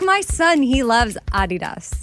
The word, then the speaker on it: Adidas: Yeah, well, that's the fun fact, is that Americans pronounce Adidas wrong. It's supposed to be Adidas, with a slight emphasis on the first syllable, not Adidas.